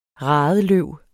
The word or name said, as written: radeløv